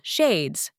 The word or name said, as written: shades